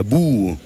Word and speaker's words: boha: le nom est un peu piégeux parce que en gascon on l'écrit B-O-H-A, mais ça n'est pas un bois. Et comme le O se prononce OU et le A final se prononce O, c'est vraiment pas la simplicité, mais c'est voilà.